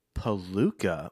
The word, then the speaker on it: Polucca: Palooka. Will you spell that for me? – P-O-L-U-C-C-A.